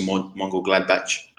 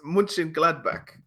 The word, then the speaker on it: Mönchengladbach: And when Favre was at Borussia Mönchengladbach, they... What? What'd I do? – It just sounded funny. – Mönchengladbach. – It's Mönchengladbach, I think. Sorry. Is how you say it.